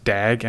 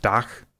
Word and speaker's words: dag: You expect it to be pronounced dag, and it's pronounced dach. It's a sound that doesn't show up a lot in English, but I really like.